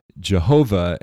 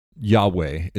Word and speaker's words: Yahweh: Yod Vav Hey, sacred tetragrammaton, and he decided it was pronounced Jehovah. And scholars today believe it's more scholars. That's a cop out. I think it's more common to see people believe that it's Yahweh is how you would pronounce mm-hmm. that rather than Jehovah.